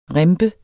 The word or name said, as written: rimpe